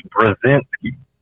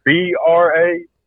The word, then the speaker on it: Brzezinski: Can you give that? Can you spell that for us rednecks over here? – I sure can, because I'm a redneck, but I got an odd last name. It is Brazinski. – Oh, Brzezinski.